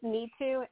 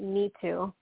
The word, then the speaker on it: Nitu: It's Neetu, N-I-T-U. You pronounce it Neetu. – N-I-T-U.